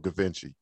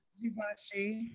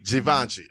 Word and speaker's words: Givenchy: Is it called pronounced Da Vinci or Gavinci? Givenchy. – Givenchy.